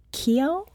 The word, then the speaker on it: Keough: and Harry Keough. – Yes. – Okay. Yes. Am I pronouncing that I right? I think you are.